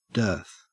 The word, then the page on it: dearth: Australian English